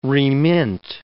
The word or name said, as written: remint